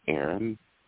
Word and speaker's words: Aaron: My name is Aaron.